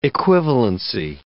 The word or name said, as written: equivalency